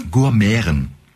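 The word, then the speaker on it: Gurmiaren: Gourmeren. – Gourmeren. – Also G-U-R, ja, okay. Und dann das Schöne, M-I-A-R-E-N, das wird, das I-A wird wie Ä ausgesprochen.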